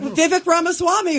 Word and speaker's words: Vivek Ramaswamy: Vivek Ramaswamy, who's running for president.